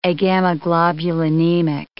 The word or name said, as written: agammaglobulinemic